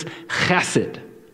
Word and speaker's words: Chesed: The word is chesed.